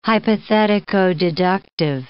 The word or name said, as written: hypothetico-deductive